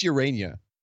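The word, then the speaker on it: Ureña: Is it Urena or Urania? – I'm pretty sure it's Urania. – Yeah, which is weird because that's not how it's spelled, but I will trust you because that seems to be the way lots of people are saying it.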